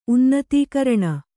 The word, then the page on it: ಉನ್ನತೀಕರಣ: Kannada